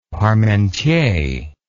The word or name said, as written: parmentier